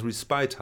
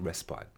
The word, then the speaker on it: respite: Und das heißt, das wird geschrieben RE und dann SPITE und das können wir nach normalen Aussprachekriterien, müsste das respite heißen. Mhm. Aber es spricht sich respite.